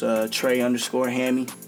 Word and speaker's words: Trey_Hammy: It's Trey_Hammy.